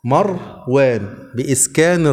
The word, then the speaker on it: مروان: واحد يقول لك فلان ابن مروان، ابنك اسمه، يقول له اسمه مروان، ما اسموش مروان، اسمه مروان، مروان بإسكان الراء.